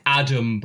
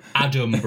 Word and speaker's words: Adam: And quite aptly for this podcast, I am frequently called Alan. even when it's got to the point where I have to do a sort of dramatic over pronunciation of my name when booking a table or something, like Adam Brooks, Adam Brooks.